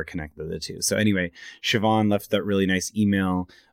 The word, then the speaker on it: Siobhan: And so it's spelled S-I-O-B-H-A-N. And it's an Irish name. So it's Siobhan, which like the name Siobhan makes sense.